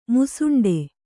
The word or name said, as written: ಮೂಸುಂಡಿ